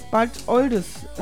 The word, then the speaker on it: Bad Oldesloe: Aus Bad Oldesloe mit, Adesloe, wie spricht man das? – Bad Oldesloe.